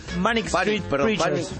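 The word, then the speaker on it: Manic Street Preachers: Manic Street Preacher.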